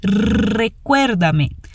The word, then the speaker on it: Recuérdame: Agora, atenção à pronúncia do R vibrado no início. Recuérdame.